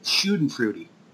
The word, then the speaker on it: Schadenfreude: The other thing that I thought was funny was, Frothy, how you pronounced Schadenfreude. I thought you said Schadenfreude or something like that, and I just had to correct you that Schadenfreude.